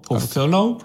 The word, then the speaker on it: Profhilo: P-R-O-F-H-I-L-O. Profilo. – Profilo. – Yeah.